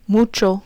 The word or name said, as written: mucho